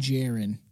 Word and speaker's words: Jaron: Named Jaron. It's Karen with a K, everybody. I mean, J. It's Karen with a J.